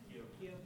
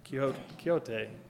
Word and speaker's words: Quixote: The musical Man of La Mancha, the song sung by Don, last name, I still don't know how to pronounce that, Quixote, maybe somebody can help me there? Quixote. That right, Quixote, that's exactly what I was going to say. It's going to be like Quixote, so thank you for that.